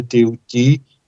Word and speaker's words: Thoth: através de um grande ser chamado Thoth, em egípcio se pronuncia Teuti, egípcio antigo, o deus Thoth, que é conhecido como Hermes Trismegisto, o Hermes três vezes grande.